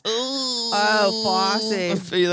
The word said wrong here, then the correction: Fauci: That's not how you say it. You are Italian. What are you doing? – Fossey. – I said Fossey. – It's Fauci.